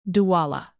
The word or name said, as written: douala